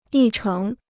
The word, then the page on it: 议程: yì chéng